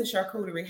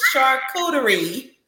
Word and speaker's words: charcuterie: How do you even pronounce charcuterie? Because I did have a customer to call and say, can I get a charcuterie from you? No, baby. Ain't nobody selling no coochie over here. Okay. It's charcuterie. Charcuterie. So I have a little educational piece in my workshop too.